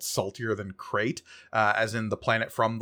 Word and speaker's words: Krayt: But it is spelled K-R-A-Y-T, as opposed to how it's, like, there is a Saltier Than Crate C-R-A-I-T.